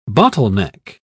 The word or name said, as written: bottleneck